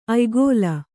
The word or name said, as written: ಆಯುಗ